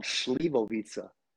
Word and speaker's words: Slivovica: It's actually called Slivovica. – Okay, yeah, I was saying it wrong.